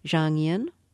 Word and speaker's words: Zhang Yin: And you write in your book about, tell me if I'm pronouncing her name correctly, Zhang Yin.